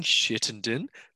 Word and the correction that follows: Chittenden: but it's Chittenden, isn't it? – I say Chittenden, yeah.